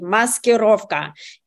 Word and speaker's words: maskirovka: You just nailed it, Dr. Savage, and you even pronounced it perfectly. Maskirovka.